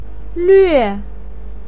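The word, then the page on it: luue4: pinyin; tone 4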